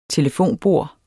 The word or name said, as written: telefonbord